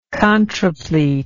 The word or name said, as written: contrapletal